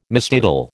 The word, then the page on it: mistitle: American English